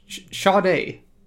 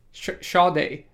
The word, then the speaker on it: Sade: But Sade. Sade. – Yeah. – Sade. – Yeah. – Spelled like Sade, but pronounced Sade.